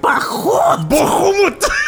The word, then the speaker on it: Bahamut: So at the top of the cliff also is a glittering statue of the dragon god of justice, Bahamut. – Bahamut. – That's in the original. That is the pronunciation of the original Draconic.